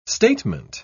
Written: statement

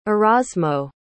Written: erasmo

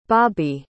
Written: bobbye

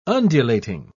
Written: undulating

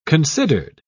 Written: considered

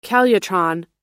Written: calutron

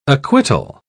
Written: acquittal

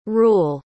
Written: reuel

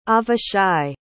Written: avishai

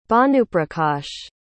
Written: bhanuprakash

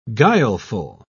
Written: guileful

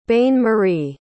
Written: bain-marie

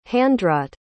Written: handwrought

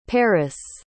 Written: perris

